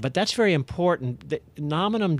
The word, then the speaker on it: Nominum: Well, to that, I mean, you guys did an uh, acquisition of, uh, is it Nominum? Is that how you pronounce the company that you bought? – Yes.